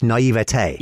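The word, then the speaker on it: naïveté: It's naivete, with that acute accent on the E.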